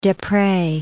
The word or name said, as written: Desprez